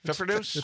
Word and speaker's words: Pfeffernuss: How do you pronounce that? – Pfeffernuss? – I remember us last year talking about how to pronounce this word. A year ago, we were also arguing over how to pronounce this. – Pfeffernuss? – I think it's Pfeffernuss, yes.